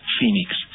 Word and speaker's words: Feenix: I'll spell it as F-E-E-N-I-X, Phoenix.